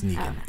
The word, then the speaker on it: Negan: Is it pronounced Negan or Negan? – Negan. Negan. It's Negan.